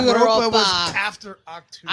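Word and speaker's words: Achtung: Say that again. How is that pronounced? I'm Octoon. – Octoon, baby. – All right. – It means attention. – It does mean attention.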